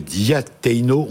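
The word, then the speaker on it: Diateino: Alors ce livre, DRH, mission ou démission, c'est chez Diateno, on prononce comme ça ? – Exactement.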